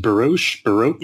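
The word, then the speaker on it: Biroc: Joseph, is it pronounced Baroque? – Yeah.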